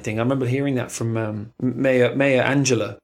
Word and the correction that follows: Angelou: Maya, do I pronounce her name? Maya Angelou. Maya Angelou.